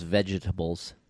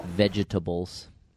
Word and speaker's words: vegetables: You're the only person I know who says vegetables. – Vegetable. – Vegetables. – Really? – I like that. – I don't know. – It's good. – How do you say it? – Vegetables. – You're missing... – Of course. – A vowel. – The I is silent.